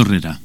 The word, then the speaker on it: Herrera: Alberto Herrera. – ¿Puede deletrearme el apellido? – Sí. H-E-R-R-E-R-A.